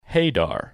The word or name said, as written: Hadar